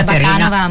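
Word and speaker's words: Bakanova: È l'usanza di pronunciare tutti i cognomi slavi, anche quelli della Repubblica Ceca, di Bulgaria, che finiscono con va. Noto questa tendenza in Italia, però originalmente l'accento cade su un'altra sillaba, perciò io mi sono abituata di essere chiamata Bacanova.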